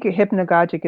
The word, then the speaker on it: hypnagogic: I think hypnagogic is the way that it's pronounced, or hypnagogic, I think, is also you could say.